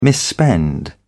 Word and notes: misspend: British English

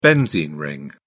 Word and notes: benzene ring: British English

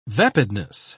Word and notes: vapidness: British English

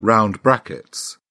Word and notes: round brackets: British English

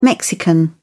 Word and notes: mexican: British English

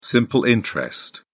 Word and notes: simple interest: British English

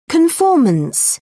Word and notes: conformance: British English

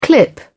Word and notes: clip: British English

